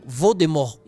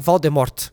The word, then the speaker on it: Voldemort: Rowling ela queria que a pronúncia do nome fosse igual ao original, sem o T no final. Então, Voldemort e não Voldemort. Então, assim, esse pedido ele foi negado para enfatizar o nome, da, o nome da palavra morte no nome do vilão e tal.